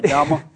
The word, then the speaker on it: Dhamma: You have said that you consider these times the era of Dhamma. Am I saying that right, Dhamma? – yes, right. Okay.